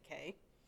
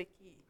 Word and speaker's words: Cay: I think it's Castaway Key. – I don't know. – Even though it's spelled like K. – Okay. – Like C-A-Y.